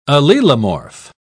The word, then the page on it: allelomorph: British English